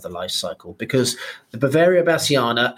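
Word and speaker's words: Beauveria bassiana: Now, let's talk about the main ingredient here, which is Boveria bassiana. Am I pronouncing that correctly? – That's absolutely correct.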